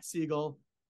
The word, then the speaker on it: Segal: So on social, you know, we have our at Segal Law Firm. It's S-E-G-A-L. Sounds like the bird, but it's spelled S-E-G-A-L Law Firm.